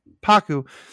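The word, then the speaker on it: Pakku: Paka and, I'm sorry, get his name wrong. – Paku and his backstory with Gran-Gran, or 5 more minutes that deepens Yue and Sokka.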